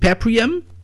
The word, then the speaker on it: Paprium: Is it Paprium? – Yeah, it is.